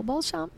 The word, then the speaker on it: Beauchamp: And with us today, we have paranormal investigator, author, and public speaker, Nicole Beauchamp.